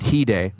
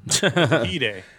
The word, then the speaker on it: Hyde: No, I know, but just maybe in Marvel, you pronounce it He-Day. – He-Day.